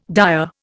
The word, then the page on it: dyer: Indian